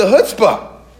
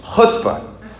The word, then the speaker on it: chutzpah: But God says, where's the chutzpah? I'm not saying it right. You got to get to the throat. You got to say chutzpah.